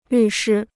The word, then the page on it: 律师: lǜ shī